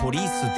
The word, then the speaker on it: police: 警察はポリスって伸ばすけど、テクノポリスの方は普通にポリス。